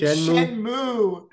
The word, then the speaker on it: Shenmue: Shyamu the Animation, Fina Pirate Princess, and a couple others. – Did you just pronounce it Shyamu? It's Shenmue. Shenmue.